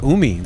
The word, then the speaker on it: Umi: Another artist that's on this project titled Table of Context is Umi.